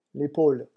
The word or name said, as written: l'epaule